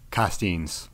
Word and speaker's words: Costeines: Costeines.